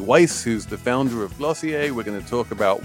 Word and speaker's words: Glossier: Okay, so you have written a book about the beauty industry in general, and now help me pronounce this company's name. – Glossier. – Glossier. – Yeah, a little bit fake French.